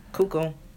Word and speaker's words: Cuoco: And Pete Davidson and Kaylee Cuoco will, I think that's how you pronounce her crazy name. – Yeah, Cuoco.